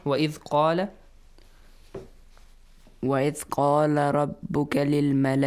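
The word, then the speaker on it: قال: So, قَا has a heavy sound. وَإِذْ قَالَ. Not وَإِذْ قَالَ. No. وَإِذْ قَالَ. If you pronounce it with قَالَ, it's kind of weird. It's not Arabic, is it? – No.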